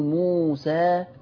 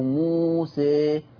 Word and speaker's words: موسى: يعني شوف، حتى لاحظوا معايا كده صوت الألف لما بقول موسى أو موسى، الصوت مختلف، فقلت